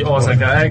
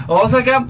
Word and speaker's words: Osaka: E allora stiamo a Osaka, siamo virtualmente, anzi Osaka, ecco, l'avevo detto bene all'inizio, adesso poi ho sbagliato. Osaka, la città i cui simboli sono la viola, il fiore, e per quanto riguarda un albero, il sakura in Giappone.